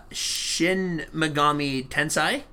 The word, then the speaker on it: Shin Megami Tensei: Shin Megami Tensei? – Right.